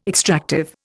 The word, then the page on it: extractive: Indian